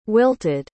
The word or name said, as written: wilted